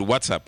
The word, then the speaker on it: WhatsApp: Sí, ella dice WhatsApp, que es lo correcto. Yo lo digo bien boricua, el WhatsApp.